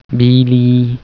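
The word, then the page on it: arbu: Logudorese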